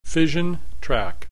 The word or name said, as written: fission track